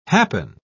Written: happen

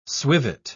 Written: swivet

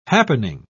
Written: happening

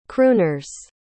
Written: crooners

